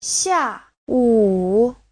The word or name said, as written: xia4wu3